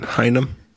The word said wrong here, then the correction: Hynek: Hynem? What? – Hynek? – What? Okay. Sorry. I mispronounced that.